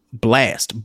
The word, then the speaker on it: Blxst: It's called Overrated, and it's by an artist by the name of Blast, but it's spelled BLXST, like Blast without the A, with an X instead of an A.